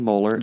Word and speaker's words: Mohler: Just type in my name, J-E-R-A-M-Y, Jeremy Moeller, M-O-H-L-E-R.